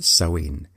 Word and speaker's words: Samhain: It's spelt Samhain but pronounced Samhain.